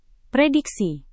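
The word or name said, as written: premium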